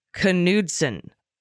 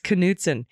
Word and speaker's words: Knudsen: The Attorney General of Montana's name is pronounced Knudsen. Got to pronounce the K. Oh, we were calling him Knudsen. Like, we were doing the silent K. It's Knudsen.